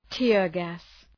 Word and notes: tear gas: ‘teərgæs

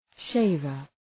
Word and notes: shaver: ‘ʃeıvər

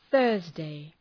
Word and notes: Thursday: ‘ɵɜ:rzdı

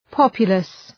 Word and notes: populous: ‘pɒpjʋləs

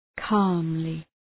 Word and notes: calmly: ‘kɑ:mlı

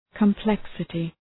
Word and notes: complexity: kəm’pleksətı

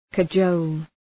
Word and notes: cajole: kə’dʒəʋl

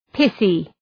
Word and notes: pithy: ‘pıɵı

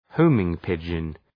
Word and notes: homing pigeon: ‘həʋmıŋ,pıdʒən

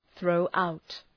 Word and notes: throw out: ‘ɵrəʋ,aʋt

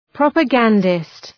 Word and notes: propagandist: ,prɒpə’gændıst

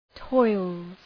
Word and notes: toils: tɔılz

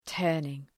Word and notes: turning: ‘tɜ:rnıŋ

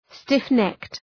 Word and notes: stiff-necked: ,stıf’nekt

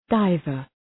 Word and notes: diver: ‘daıvər

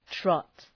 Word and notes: trot: trɒt

